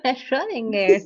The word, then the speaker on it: Schrödinger: Vino un físico, a ver si me sale la pronunciación, Erwin Schrödinger, ¿te suena? – Sí, es la caja de Schrödinger. Ay, no lo puedo pronunciar tampoco. – Schrödinger, ajá. – Schrödinger. – Schrödinger, no sé. – Dilo conmigo. Schrödinger.